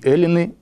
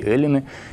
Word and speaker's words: эллины: «Есть и эллины, и иудеи». То есть это надо читать вот с ударением на первый слог. «Есть и эллины, и иудеи».